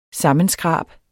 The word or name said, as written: sammenskrab